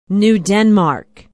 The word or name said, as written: new denmark